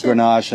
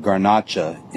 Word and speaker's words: Grenache: And it's pronounced Grenache in the States and Garnacha in Spain.